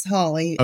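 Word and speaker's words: Hawley: I was like, is it Holly or like Haw-ly?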